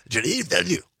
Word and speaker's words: Villeneuve: You have to say it quickly with a lot of throat and tongue. Denis Villeneuve. Like that.